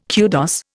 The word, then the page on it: kudos: Indian